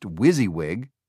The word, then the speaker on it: WYSIWYG: WYSIWYG, pronounced WYSIWYG, an acronym for "What you see is what you get."